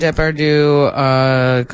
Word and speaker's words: Depardieu: Gerard Depardieu completely...